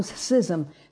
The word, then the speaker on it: schism: But God composed the body, having given greater honor to that part which lacks it, that there should be no schism. Now that's how you pronounce that word, and I hear it pronounced schism, or it's schism, like scissors.